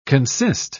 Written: consist

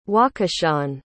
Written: wakashan

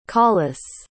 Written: collice

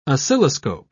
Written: oscilloscope

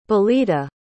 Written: belita

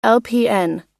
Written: lpn

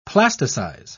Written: plasticize

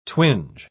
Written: twinge

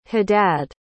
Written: haddad

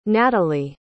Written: nataly

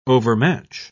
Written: overmatch

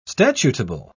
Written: statutable

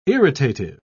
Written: irritative

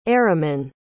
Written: eraman

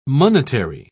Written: monetary

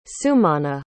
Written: sumanna

